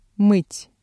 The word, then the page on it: мыть: Russian